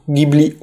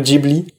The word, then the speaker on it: Ghibli: Et pour la petite anecdote sur la juste prononciation du nom du studio, Ghibli ou Ghibli, les deux se valent. En effet, si vous voulez le prononcer à l'italienne, comme il devrait normalement se prononcer puisque le mot est d'origine italienne, il se prononce Ghibli, mais au Japon il a été popularisé et retranscrit sous le nom Ghibli, qui est aujourd'hui l'orthographe utilisée au pays du soleil levant.